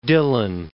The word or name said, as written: Dylan